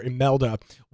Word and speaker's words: Imelda: It starts with an I, but it's pronounced, for at least from what I've been able to gather, Imelda will be the name of this storm.